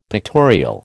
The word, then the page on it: pictorial: American English